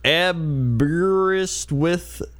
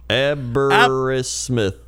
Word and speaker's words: Aberystwyth: Aberystwyth. Though the last syllable rhymes with Smith rather than with. Aberystwyth.